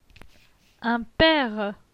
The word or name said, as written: Un pere